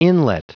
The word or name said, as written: inlet